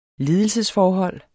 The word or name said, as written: ledelsesforhold